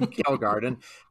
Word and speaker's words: Kjellgaard: depending on what country you're from, it's pronounced differently. So we're going to just go with Jim Kjellgaard, and he's sort of like Jack London light.